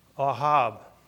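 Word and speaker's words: Ahav: And you pronounce it like this. Ahab.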